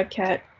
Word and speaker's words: Khat: Yeah, Khat.